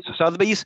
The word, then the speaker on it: Sotheby's: The auction house, is it Sotheby's? – Sotheby's.